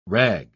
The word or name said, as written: rag